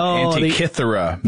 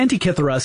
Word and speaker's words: Antikythera: Yeah, it all depends on which pronunciation you follow. But Antikythera seems to be fairly commonplace. So we're going to go ahead and use that one and probably switch off without even thinking about it.